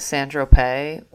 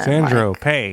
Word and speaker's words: Saint-Tropez: When I was little, I used to think that Saint-Tropez was like S-A-N-D-R-O as one word, like Sandro. And then Saint-Tropez. – Like, pay, yeah, like P-E maybe.